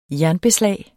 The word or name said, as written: jernbeslag